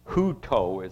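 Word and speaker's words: huto (οὕτω): Here's the word that John used, number G3779, that is translated as "so," or it's "huto" is the way it's pronounced, meaning "in this way."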